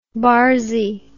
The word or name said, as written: barzy